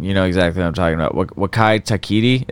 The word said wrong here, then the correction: Taika Waititi: is that how you say it? – Oh, uh, Taika Waititi.